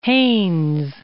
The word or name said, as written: Haynes